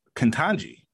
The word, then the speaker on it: Ketanji: Joe Ketanji.